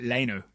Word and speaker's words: Luiz: For the record, Arlo White pronounces it Leno.